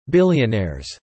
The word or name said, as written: billionaires